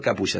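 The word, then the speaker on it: Capuya: Arroba doctor, sí, con todas letras, Capulla. Doctor Capulla, CAPUY. ¿Sabes que en Japón hay mucha... Hay muchos nombres que terminan en Uya, con, igual, se escribe igual con Y.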